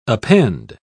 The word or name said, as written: append